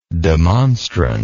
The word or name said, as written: demonstrant